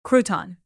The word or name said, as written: crouton